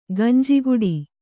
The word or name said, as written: ಗಂಜಿಗುಡಿ